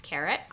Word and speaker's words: Caritte: Spelled C-A-R-I-T-T-E, but it's pronounced Carrot.